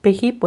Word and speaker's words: Payhip: PayHip, bueno, pues, si lo estás escuchando se escribe P-A-Y-H-I-P, ¿vale?